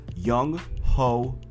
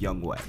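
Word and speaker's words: Younghoe: His name, phonetically pronounced, Young Ho Koo. – Mm-hmm. – But apparently it's pronounced Young Wei.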